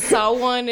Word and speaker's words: Samhain: It's spelled Samhain. but it's Sawan, and it's one of those things that's like in between an equinox and a solstice, whatever.